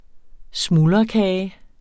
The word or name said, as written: smuldrekage